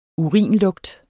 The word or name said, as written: urinlugt